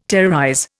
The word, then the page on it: terrorize: Indian